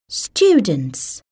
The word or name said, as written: students